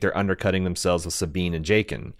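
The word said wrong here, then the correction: Jacen: Or Jason? It's Jason. I always pronounce it Jaqen when I was reading it growing up, but I guess it's Jason. Sabine appears to be in her late teens,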